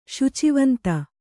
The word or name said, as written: ಶುಚಿವಂತ